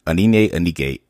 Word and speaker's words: Onyi: She is Onyi, and I'm sorry if I butchered your name.